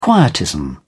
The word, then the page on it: quietism: British English